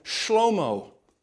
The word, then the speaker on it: Shlomo: If we use the Hebrew pronunciation, we'd call him Shlomo.